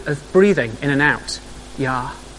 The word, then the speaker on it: Yahweh: They added some vowels so that you can say it, so the A and the E get added, so it becomes Yahweh. So the name Yahweh.